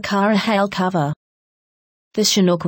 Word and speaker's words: Chinook: They are often caused by a phenomenon called a Chinook, pronounced Chinook.